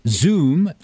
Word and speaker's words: Soom: And our guest is Amy Zeidelman with Zoom. Do I pronounce that correctly? S-O-O-M, tahini, yeah.